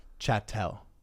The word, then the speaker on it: chattel: Their name for it is chattel. – Chattel. – Just like cattle. – Yep. – So, yeah. So, you know, and this goes... – Is it spelled like cattle? – It's C-H-A-T-T-E-L.